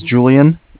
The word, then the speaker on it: Julian: Okay, my name is Julian. It's J-U-L-I-A-N.